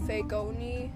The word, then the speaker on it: Fagoni: No, actually, it's pronounced Fagoni, right? My name, right? But it sounds like fake knees.